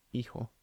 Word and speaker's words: hijo: And where's the stress in each of the words? Hijo, hija. – The second one. – No, it's on the first syllable. – Hijo, hija. – Yeah, first syllable in each case.